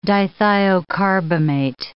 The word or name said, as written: dithiocarbamate